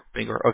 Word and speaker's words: Bangor: Am I pronouncing that good, Bangor? – Yeah, that's right. – Bangor, okay.